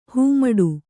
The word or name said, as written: ಹೆಮ್ಮಾಡಿ